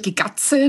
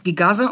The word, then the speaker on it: Gigatze: Genau, also es wird Gigatze, nicht wie du es gesagt hast, Gigaze ausgesprochen. Mhm.